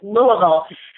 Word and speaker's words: Louisville: Right, it's Louisville, correct? – Perfectly, yeah, perfect pronunciation is Louisville.